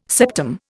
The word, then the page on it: septum: Indian